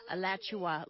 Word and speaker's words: Alachua: Alachua? Is that how you say it? – Alachua, like Joshua, but Alachua.